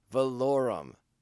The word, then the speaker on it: Valorum: Ian Ruskin as Chancellor Valorum. Valorum. Yeah, that's right. Tim Curry doesn't say it right in this episode.